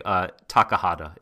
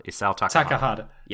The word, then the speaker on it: Isao Takahata: Takahata. Isao Takahata.